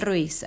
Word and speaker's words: Ruiz: How do you say that in English? Like Ruiz, Don Miguel Ruiz.